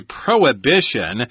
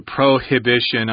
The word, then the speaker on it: prohibition: So, again, this is a prohibition. You can pronounce that H if you want to. Prohibition. I say prohibition.